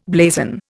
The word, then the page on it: blazon: Indian